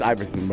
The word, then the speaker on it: Iverson: It's I-V-E-R-S-O-N. – Yeah, how do you pronounce that? – Iverson. – But you said I-V. Iverson.